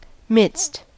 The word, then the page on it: midst: American English